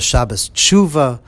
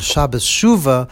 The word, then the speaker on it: Shabbos Shuva: Some people pronounce it as Shabbos Tshuva because it is the Shabbos about doing tshuva, but of course it's officially known as Shabbos Shuva after the first word of the Haftorah tomorrow morning.